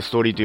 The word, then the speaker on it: 物語: デジタルデビル物語と書いてストーリーと読む